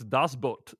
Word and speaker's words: Das Boot: It's Das Boot.